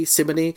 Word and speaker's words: simony: Hang on, what's himony? Himony, himony, himony who? – Heresy. Did I say himony? – I thought you said himony. – No, I shouldn't have. Simony. I said simony, which is lent.